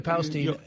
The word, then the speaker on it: Palestine: When he showed up in Palestine, did I say that right? – Yeah. – Palestine.